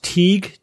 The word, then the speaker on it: Tighe: This one comes from, I believe the name is pronounced Teague, T-I-G-H-E.